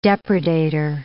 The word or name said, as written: depredator